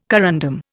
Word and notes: corundum: Indian

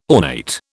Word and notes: ornate: British English

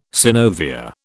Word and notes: synovia: British English

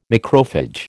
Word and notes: macrophage: American English

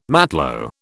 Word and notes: matlo: British English

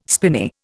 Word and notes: spinney: Indian

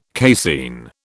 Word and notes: casein: British English

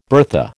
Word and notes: bertha: American English

